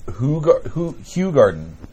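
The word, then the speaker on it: Hoegaarden: The beer is pronounced Hugh Garden.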